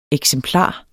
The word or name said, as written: eksemplar